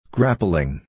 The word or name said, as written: grappling